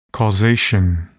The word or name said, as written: causation